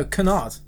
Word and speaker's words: canard: If anything, it's going to be silent K. – No, it's a C. – That's a nard. – No, it's a C. Yeah. You know. – So canard. Sunard. – No, canard. – Anyway, yeah.